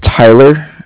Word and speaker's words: Tyler: Yeah, my name is Tyler, T-Y-L-E-R. I'm calling with Cardiff. It's spelled C-A-R-D-I-F-F, as in Frank.